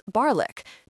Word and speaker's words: Barnoldswick: Barnoldswick becomes Barlick, Chulminston becomes Chumpson.